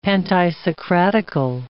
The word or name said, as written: pantisocratical